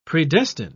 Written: predestine

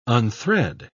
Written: unthread

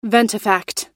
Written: ventifact

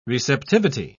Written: receptivity

